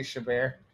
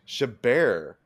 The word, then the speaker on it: Chabert: Lacey Chabert. – Chabert. Okay, so the emphasis on the first syllable. Okay. Or no, second syllable. Second syllable.